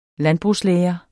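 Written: landbrugslærer

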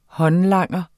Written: håndlanger

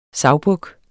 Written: savbuk

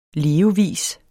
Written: levevis

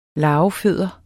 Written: larvefødder

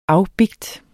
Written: afbigt

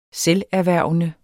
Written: selverhvervende

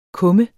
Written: kummer

